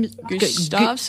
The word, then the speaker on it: Gustavsson: So uh, Tony, G- I'm not sure how to pronounce his name, Gustavsson. G- Gustavsson. – I'll go with that.